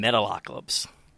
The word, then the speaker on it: Metalocalypse: our favorite animated dude from Metalocalypse. – I'm so impressed you said the name right.